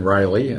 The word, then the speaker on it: Reilly: Yes, it's Kevin Riley, and the last name is spelled R-E-I-L-L-Y.